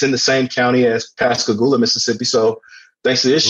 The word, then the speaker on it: Pascagoula: And you guys said it right. – Pascagoula.